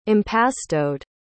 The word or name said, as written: impastoed